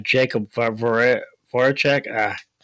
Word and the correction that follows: Jakub Voracek: Jakub Voracek.